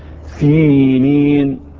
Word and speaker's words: سينين: اقول سينين، اكيد في خطأ بيحصل، ما تشغلش بالك ايه اللي بيحصل بالخطأ. انا النهارده يهمني اليوم، يهمني النطق الصحيح. خلاص الصحيح سينين.